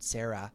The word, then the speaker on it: Serra: His name's Richard Serra. I think that's how you pronounce his last name, S-E-R-R-A. – Yep, that's right.